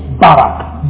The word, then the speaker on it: Barak: Now that word, I will bless you, is the word in the Hebrew, Barak. Not Barack Obama now, but Barak. B-A-R-A-K. And I'll make you a blessing is another word, Baraka. Or Beraka, sorry, Beraka. B-E-R-A-W-K-A-W.